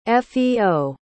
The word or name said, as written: feo